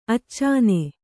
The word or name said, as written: ಅಚ್ಚನ